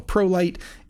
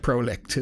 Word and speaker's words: ProLycht: It's called a Pro Light. It's spelt strangely. It's ProLycht, which I guess is German for light, is what I've heard. But it looks like Prolicht is what it looks like, but it's Pro Light, and it's called the Orion F300FS, or full spectrum.